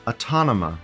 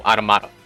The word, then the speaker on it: Automata: Autonoma, like automation. – No, Automata. – Automata. – Yep. Like Automata. Automata.